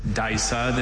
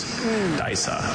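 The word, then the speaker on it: Disa: Dysa, this is a showy tropical African terrestrial orchid with tuberous rootstocks and dark green leaves. Dysa.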